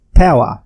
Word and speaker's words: paua: It's called, it's actually written P-A-U-A and pronounced power, sort of power.